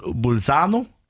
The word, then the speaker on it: Bolzano: Den skulle jo egentlig startet i Bolzano var fin uttale Mm.